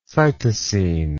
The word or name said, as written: cytosine